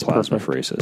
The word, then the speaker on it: plasmapheresis: I don't know if I'm pronouncing that right. – Yeah, that's plasmapheresis. – Perfect.